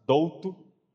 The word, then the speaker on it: Dolto: Em português a gente simplesmente pronuncia mais fácil Douto, D-O-L-T-O.